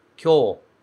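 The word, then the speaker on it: kyō (今日): Na primeira parte da frase, a gente tem kyō. Kyō. Esses dois kanji se pronunciam kyō e significam hoje.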